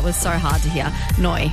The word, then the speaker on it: Noi: Noi. – It was Noi. – Noi. – It's spelled N-O-I if you want to go.